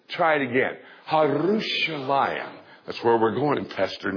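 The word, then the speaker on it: Harushalayim (ירושלים): Let me tell you how you say it in Hebrew. Harushalayim. And you have to twirl your tongue. Harushalayim. Try that. Harushalayim.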